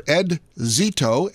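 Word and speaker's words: Ed Szeto: Now, my other guest is a phone guest on the line here today, and that is Mr. Ed Zeto, S-Z-E-T-O.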